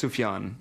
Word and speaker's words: Sufjan: Do you pronounce it Sufjan or? – I think it's Sufjan. – Sufjan Stevens. – Sufjan Stevens. – I don't know. I always said Sufjan until somebody corrected me.